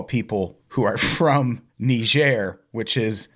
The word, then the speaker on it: Niger: Yeah, it covers the ambush killing of four American Special Forces troops by ISIS militants in Niger, which I always pronounced Niger. – Yeah, I think the classic American pronunciation is Niger, but I think it's supposed to be Niger.